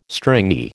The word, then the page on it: stringy: American English